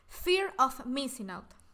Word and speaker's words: Fear of Missing Out: Entonces, bueno, el FOMO es, según sus siglas en inglés, o sea, significa Fear of Missing Out.